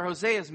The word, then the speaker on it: Hosea: Hosea's name, actually in the Hebrew, should be pronounced Hosea. It is the same word, the same root as for Yeshua or Joshua. It means salvation.